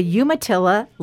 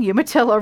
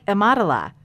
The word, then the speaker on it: Umatilla: I checked out the first lesson, and I found out I was saying Umatilla wrong. – Is it Umatilla? – And I'm not sure still if this is the correct way to say it, but it's more like Umatilla.